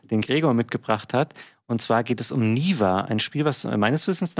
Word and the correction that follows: Neva: Neva wird es ausgesprochen im Spiel.